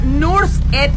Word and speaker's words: Nusret: Se llama Nursed. Se escribe Nursed, así como Nusret.